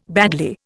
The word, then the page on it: badly: Indian